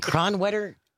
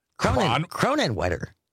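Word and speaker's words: Kronenwetter: Julianne in Cronwetter. Cronenwetter.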